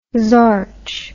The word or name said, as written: zorch